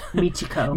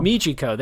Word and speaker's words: Michiko: Michiko. – Michiko, there we go.